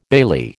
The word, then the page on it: bailey: American English